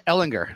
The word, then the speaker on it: Ehlinger: No, El, no, no, Ehlinger, Ehlinger.